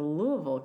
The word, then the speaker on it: Louisville: I am so excited to report that I left South Florida, got on a plane, and went to Louisville, Kentucky.